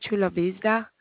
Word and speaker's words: Chula Vista: I'm not saying, no, if I'm pronouncing it right, but Chula Vista, C as in, I'm going to spell it for you, C as in cat. – You got it right.